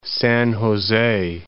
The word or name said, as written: San Jose